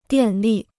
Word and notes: 电力: diàn lì

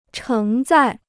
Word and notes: 承载: chéng zài

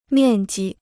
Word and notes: 面积: miàn ji

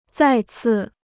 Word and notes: 再次: zài cì

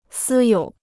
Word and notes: 私有: sī yǒu

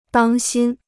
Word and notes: 当心: dāng xīn

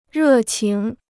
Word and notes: 热情: rè qíng

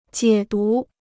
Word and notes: 解读: jiě dú

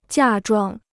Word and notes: 嫁妆: jià zhuang

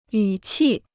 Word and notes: 语气: yǔ qì

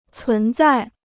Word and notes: 存在: cún zài

